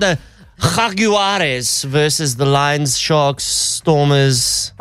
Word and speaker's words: Jaguares: They say the J is pronounced with an H. – Jaguares. – That's correct.